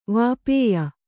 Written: ವಾಪೇಯ

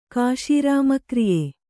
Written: ಕಾಶೀರಾಮಕ್ರಿಯೆ

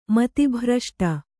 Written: ಮತಿಭ್ರಷ್ಟೆ

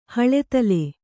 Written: ಹಳೆತಲೆ

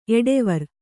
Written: ಎಡೆವರ್

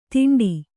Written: ಧೈಂಡಿ

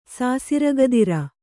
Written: ಸಾಸಿರಗದಿರ

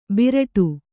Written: ಬಿರಾಡು

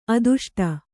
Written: ಆದಿಷ್ಟ

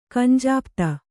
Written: ಕಂಜಾಪ್ತ

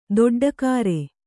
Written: ದೊಡ್ಡಕಾರೆ

